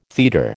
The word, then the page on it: teeter: American English